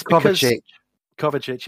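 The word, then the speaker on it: Kovačić: He named Jorginho and Kovacevic, I can never say his name. – Us are going to help you. – No, exactly. It's Kovacic.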